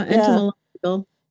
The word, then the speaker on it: entomological: Entomological warfare? Is that entomology? Yeah, entomological.